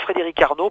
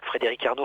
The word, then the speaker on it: Frédérick Arno: Moi, Frédéric Arnaud, pour renseigner les auditeurs, Frédéric Arnaud, Frédéric, ça s'écrit CK à la fin.